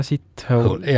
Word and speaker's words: Sithole: Jaja Sithole. – Jah. – Niimoodi hääldataksegi? – No, kirjutatakse S-I-T ja hole, nagu ingliskeeles on siis.